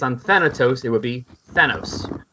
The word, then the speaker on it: Thanos: How do you say his name correctly? – Thanos. – Thanos. But we used to always say Thanos. – Yeah, because it's based on the name of like a Greek god of death, Thanatos.